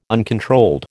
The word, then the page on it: uncontrolled: American English